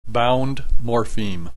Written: bound morpheme

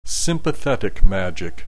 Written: sympathetic magic

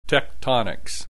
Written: tectonics

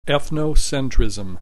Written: ethnocentrism

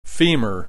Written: femur

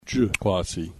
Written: Ju hoansi

